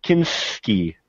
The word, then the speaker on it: Kinski: Kinski.